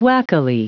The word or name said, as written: wackily